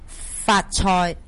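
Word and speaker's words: 髮菜: How do you say it? – Fat choy. – Fat choy is sea moss. – Yes, and it's like fat choy, which is kung hei fat choy.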